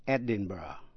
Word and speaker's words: Edinburgh: Nguồn gốc từ chữ B-U-R-G. Tên thành phố, Capital of Scotland, đọc là Edinburgh. E-D-I-N-B-U-R-G-H, Edinburgh.